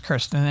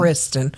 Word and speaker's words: Kirsten: It's pronounced Kirsten, isn't it? – Kirsten, K-I-R. – Yeah, Kirsten. – Not Kristen.